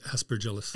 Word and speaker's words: Aspergillus: this has some asparagus in it. I'm saying it wrong. But how do you say it? – Aspergillus. – Aspergillus, exactly. I knew it. I could see it in my head. Aspergillus.